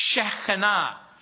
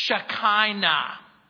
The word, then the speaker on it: Shekinah: This is what the Hebrews called Shekinah or some people mispronounce Shekinah. This is the glory cloud.